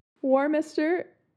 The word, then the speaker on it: Warminster: Warminster. Warminster. – Is there an N? – Yes. – Minster? – Yeah, but it's not minister.